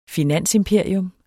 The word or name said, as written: finansimperium